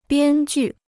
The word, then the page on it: 编剧: biān jù